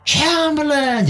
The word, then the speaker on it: Chamberlain: 张伯伦把它读成枪伯伦，嗯，Chamberlain 这么来读，那么他就是 Chamberlain 就这么读出来了，Chamberlain。